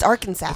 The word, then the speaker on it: Arkansas: It's Arkansas. – It's Arkansas. Arkansas. – Pronounce it right, please.